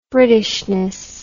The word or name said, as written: Britishness